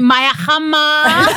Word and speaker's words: Maya Jama: Everyone says what is actually the right pronunciation? – Do you know what? My nan, who is actually the original Jama, says Jama, so really it should be that. But I think I was trying to be posh one day when I started doing telly. I'm like, Jama. I'm my Jama. And then now it's just stuck. It doesn't actually matter. It's spelled the same.